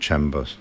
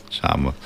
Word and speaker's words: Charmers: Ja, nicht Chambers. Ne? – Nein. – Charmers.